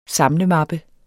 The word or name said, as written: samlemappe